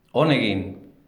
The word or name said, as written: on egin